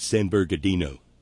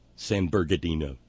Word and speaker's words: San Bernardino: Oh, I said it wrong. I'm sorry, Brother Deacon Asa Point. It's San Bernardino. San Bernardino.